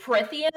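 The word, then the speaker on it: Prythian: I also really liked, some people were like kind of hating on the world building as amateur-like, but I thought, like, how do you say it? Prithrin? Prithrin? – Prithian? – Yeah, it was sick.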